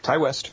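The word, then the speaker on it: Ti West: Yep, Ty West.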